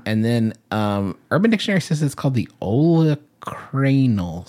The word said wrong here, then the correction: olecranon: skin. – Olecranon. That's what olecranon. Yeah, that's right. Mm-hmm. I said that wrong.